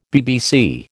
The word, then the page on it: BBC: American English